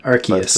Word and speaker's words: Arceus: Arceus.